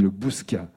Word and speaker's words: Le Bouscat: Et je crois qu'on ne prononce pas le T là, en Gironde, hein, on dit le Bouscat.